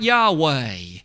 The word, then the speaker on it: Yahweh: And so this discussion, this name, as you can see at the bottom of that slide, YHWH, if we insert the vowels in English that seemingly are most correspondent to what would initially have been used for the proper pronunciation, it looks like Yahweh.